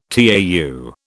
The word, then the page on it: tau: British English